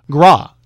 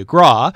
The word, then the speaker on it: Graae: Is it pronounced Gray? – Gras. – G-R-A-A-E, Gras, and they'll sing their most requested Broadway tunes and pay tribute to Broadway composer Jerry Herman.